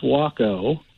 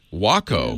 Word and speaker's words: Waco: It's pronounced Waco. – Waco.